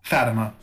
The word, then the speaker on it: Fatima: That and that is a fascinating story about Fatima, how did you say that? Brazil? – Fatima, Our Fatima.